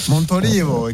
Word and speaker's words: Montolivo: Hein, ça va, je l'ai bien prononcé là. – Montolivo, excellent.